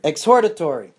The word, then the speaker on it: exhortatory: How do you pronounce that? Exhortatory. Well, to exhort means to warn, right? I don't know how to pronounce this word. – Exhortatory. – Exhortatory action, meaning to guidance, as we say.